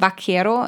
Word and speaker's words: Vaquero: beziehungsweise da ist es Vaquero, also mit, mit V geschrieben, aber ich glaube, das V im Spanischen mit, wird wie B ausgesprochen.